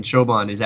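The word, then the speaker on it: çobanı: and that C is actually pronounced ch, ch, and so ch, çobani.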